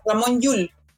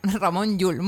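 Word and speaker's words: Ramon Llull: Ramón Llull. – Ramón Llull, muy bien, muchas gracias.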